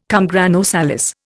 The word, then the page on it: cum grano salis: Indian